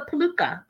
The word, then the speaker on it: peluca: Oh, is it peluca? Am I saying it right? Yeah, it's a peluca.